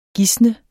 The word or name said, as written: gisne